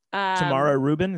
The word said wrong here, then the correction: Tamar Rubin: Is that, am I saying it right? Tamar. Tamar Rubin.